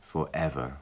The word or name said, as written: forever